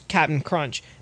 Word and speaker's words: Cap'n Crunch: Then Quaker Oats then owns Cap'n Crunch. By the way, it's Cap'n Crunch. – Yeah, that's Captain.